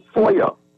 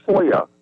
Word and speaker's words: FOIA: We pronounce FOI, FOIA. The act is called FOIA.